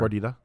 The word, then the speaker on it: gordita: Don't roll your R. – Oh, I had a good R. – Dave will be pissed. – You don't... – Just one R. – Gordita? – Gordita.